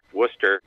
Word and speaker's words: Worcester: Worcester. Worcester, is the name of the town where the former Paw Sox are going to be with the new stadium.